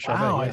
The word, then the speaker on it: Charvet: certified language and behavior consultant for Shell Rose Charvet. – Shelley Rose Charvet. Yeah, right. – Charvet, I said that right.